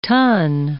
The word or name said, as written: tonne